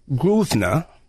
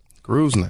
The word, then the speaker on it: Grosvenor: Richard Lewis Grosvenor. – Grosvenor.